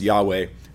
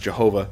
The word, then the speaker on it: Yahweh: Now, we're not sure what vowels go with those letters because it's been lost to history. It's just not known. So it may have been pronounced Yahweh. Definitely was not pronounced Jehovah. There's no J sound. Actually, in Hebrew, so we're pretty sure that they didn't make a sound they don't have, but maybe Yahweh.